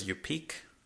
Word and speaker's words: Yupik: I think the pronunciation of the tribe, though, is Yupik.